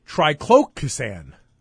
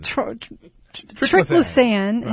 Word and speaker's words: Triclosan: And she kept saying, triclocasan.